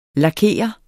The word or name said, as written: Lakerer